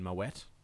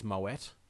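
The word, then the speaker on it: Moët: So and you get some common ones that I think aren't even worth correcting, like Moe and Moet. – Yeah. – Technically, it's Moet. – Yeah. – But everyone says Moe.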